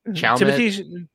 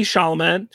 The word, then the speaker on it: Chalamet: I can't pronounce Timothy Chalamet. Timothy Chalamet.